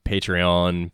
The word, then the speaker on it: Patreon: Patreon. That's how it's pronounced. Phil. It's sorry. – Like French or something.